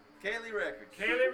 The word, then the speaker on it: Ciely: They're on the, I'll have to spell it, C-I-E-L-Y. How do you pronounce that lately? – Kaylee Records.